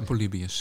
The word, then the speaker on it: Polybios: En zeg ik Polybios of zeg ik Polybios? – Dat is, ik zou Polybios zeggen.